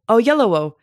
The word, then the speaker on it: Oyelowo: Yeah, I can't ever pronounce his name correctly. David Oyelowo. – Oyelowo. – Oyelowo. – Yes.